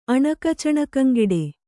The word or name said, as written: ಅಣಕಚಣಕಂಗೆಡೆ